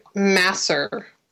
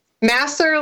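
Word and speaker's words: Masur: It's Richard Masser. Masser, like, without the T for master.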